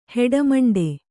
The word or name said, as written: ಹೆಡಮಂಡೆ